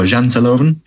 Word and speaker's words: Janteloven: Uh, Janteloven or Janteloven. That's pretty good.